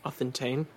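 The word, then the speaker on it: Authentane (αὐθεντεῖν): The Greek word is authentian. – Authentane, yep. – Authentane, mm-hmm. Okay. – E-I-N.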